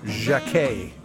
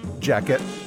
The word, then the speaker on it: Jacquet: Each of his names ends with a silent letter. You might pronounce it jacket. I think I have in the past, probably. But from now on, I'm going to say Jacquet.